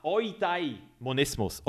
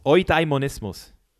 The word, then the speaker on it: Eudaimonismus: Hast du schon mal vom Eudena, Eudani, Eudanismus gehört? Sorry, Mann, ich kann es gar nicht mehr aussprechen. – Euda, Euda, ich habe ein Video darüber gemacht und ich kann es immer noch nicht aussprechen. Ah, Eudaimonismus. Eudaimonismus. Das ist der stoische Weg, der Eudaimonismus.